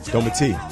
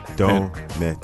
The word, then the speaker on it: Dometi: Domite. – Domite. I apologize. How do I spell that? – D-O-M-E-T-I. Domite.